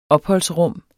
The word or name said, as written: opholdsrum